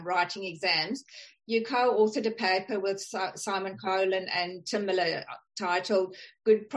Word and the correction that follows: Coghlan: Did I pronounce Simon's surname correctly? – Coghlan.